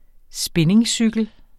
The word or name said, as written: spinningcykel